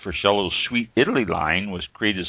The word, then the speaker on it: Freschello: Freschello, I think it's pronounced. F-R-E-S-C-H-E-L-L-O.